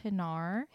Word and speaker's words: Hinar: What are the, it was, the first one is Hinar. – Hinar. – In downtown San Diego. I hope that's how you pronounce it. – Yeah. – It's H-I-N-A-R.